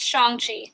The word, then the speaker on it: Shang-Chi: And by the way, on a side note, from what I read, it is pronounced Shang-Chi, not Shang-Chi.